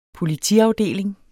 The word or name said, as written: politiafdeling